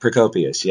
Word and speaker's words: Procopius: Is that Procopius, yes.